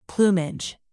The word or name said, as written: plumage